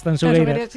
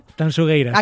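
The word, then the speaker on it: Tanxugueiras: Tanchugueiras. – Sí, esto me lo corrige Fermina, Agustín, mucho a Marta, por favor. Digo, mira que yo siempre pronuncio. A ver, otra vez, dímelo. – Tanchugueiras.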